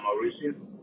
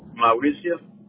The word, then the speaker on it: Mauricio: Mauricio. – I'm sorry? – Mauricio. – How do you spell it? Sorry, cut. It's M-A-U-R-I-C-I-O. – M-A-U-R-I-C-I-L? – Correct.